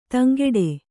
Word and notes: ದಂಕಟ್ಟು: dam kaṭṭu

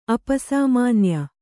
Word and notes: ಅಪಸಾಮಾನ್ಯ: apasāmānya

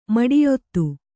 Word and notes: ಮಡಿಯೊತ್ತು: maḍiyottu; Kannada

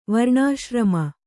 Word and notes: ವರ್ಣಾಶ್ರಮ: varṇāśrama

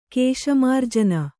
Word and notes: ಕೇಶಮಾರ್ಜನ: kēśa mārjana

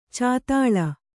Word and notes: ಚದಲು: cadalu; Kannada

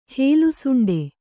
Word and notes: ಹಲ್ಲುಸುಂಡೆ: Kannada

